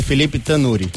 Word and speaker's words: Felipe Tanuri: Todo mundo pronuncia é meio equivocado da primeira vez, né? Felipe Tanuri. – É Tanuri, né? É, tá isso. certo colocaram aqui um acento, então ficou Tanuri.